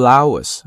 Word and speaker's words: hours: 那么为什么是这样的？就是在几个小时之内， over several hours, 啊，注意这个地方有个连读 several hours, 注意为什么呢？因为这个 hour H 不发音啊， several hours, and more than 100 tweets, 总共发了多少条？